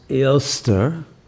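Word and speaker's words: Eostre: But the Easter holiday, the word actually comes directly from an earlier Germanic tradition, and in which the goddess Ilster, which is pronounced very much like Easter, was celebrated.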